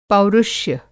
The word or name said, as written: पौरुष्य